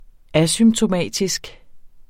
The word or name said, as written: asymptomatisk